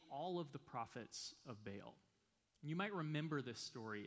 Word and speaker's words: Baal: Baal is actually pronounced Baal, but for the sake of ease, let's just call him Baal.